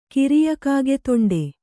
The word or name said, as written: ಕಿರಿಯಕಾಗೆತೊಂಡೆ